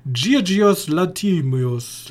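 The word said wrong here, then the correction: Yorgos Lanthimos: Jo, ich glaube, der wird Jorgos Lantimos ausgesprochen.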